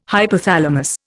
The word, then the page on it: hypothalamus: Indian